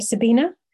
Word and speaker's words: Sabina: I pronounce my name Sabina, so I'm misspelling it here on purpose. It's my pronunciation guide spelling,